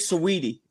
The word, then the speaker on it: Saweetie: Is it Saweetie? Is it Sweetie? How to say that? – I think Saweetie. – Saweetie. – Saweetie. Okay.